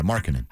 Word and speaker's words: Markkanen: Markkanen? – Markkanen? – Yeah, Markkanen.